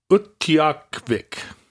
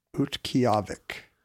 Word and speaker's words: Utqiagvik: So Barrow's Inupiat name is Utqiagvik. Utqiagvik. – Utqiagvik. The G is silent.